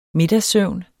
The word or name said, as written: middagssøvn